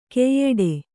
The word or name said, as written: ಕೈಯಿಡು